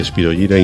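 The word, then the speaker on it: Spyro Gyra: La banda DJ Beckenstein, Spiro Gyra, se dice así, Spiro Gyra y no Spiro Gyra, porque ese nombre es un nombre latín, es el nombre de un protozoo, Spiro Gyra.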